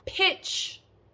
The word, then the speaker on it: pitch: Now our second word has the short I sound. We say pitch, pitch.